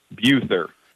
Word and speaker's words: Buther: Buther.